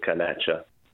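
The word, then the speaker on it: Garnacha: Yeah, so it's Grenache, but it's the Spanish, that's the way the Spanish call it, Ganache.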